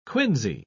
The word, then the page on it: quinsy: British English